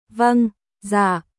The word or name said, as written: Vâng Dạ